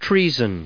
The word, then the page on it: treason: trea*son; American English